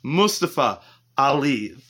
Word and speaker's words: Mustafa Ali: Mustafa Ali, that is how you pronounce it.